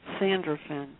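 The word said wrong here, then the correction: Sandalphon: Sandalfin. I'll spell that for you. It's S-A-N.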